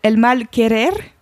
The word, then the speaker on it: El Mal Querer: Alors, je vais mal le prononcer car ça fait vraiment longtemps que j'ai pas fait d'espagnol, qui s'appelle El Mal Querer. – Oh, tu prononces trop bien.